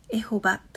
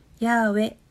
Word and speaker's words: ヤーウェ: エホバという発音は俗説。最新の研究では、ヤーウェとされている。